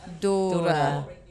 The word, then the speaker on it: Doerr: I have been corrected on my pronunciation. So Yolene Doerr. Is that right? – Yeah. – Okay. – Always do the E at the end as an... – Doerr. – That's it.